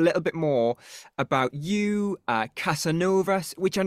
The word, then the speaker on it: Casanovas: In Swedish, we pronounce it Casanovas.